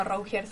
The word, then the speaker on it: Rougier: Eh, no, Rougier se dice.